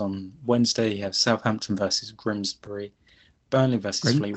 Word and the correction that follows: Grimsby: Grimsby. How did you say it? Grimsby.